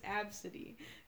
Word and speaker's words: Abcde: Her name is Absidy. Guess how it's spelled. – Absidy. – A B C D E. She's named after her father's cat. Her father's cat was named Absidy. A B C D E. That's her name.